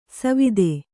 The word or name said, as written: ಸವಿಧೆ